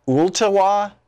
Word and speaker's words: Ooltewah: Becky in Ooltewah, Tennessee.